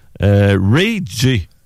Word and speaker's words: Ray J: Ray J. Ça s'écrit R-A-Y-J, là, parce que le J se prononce J en anglais, bien sûr.